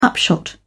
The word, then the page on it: upshot: British English